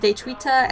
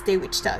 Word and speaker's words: Daechwita: Also, I mispronounced Daechwita as Daewichta again.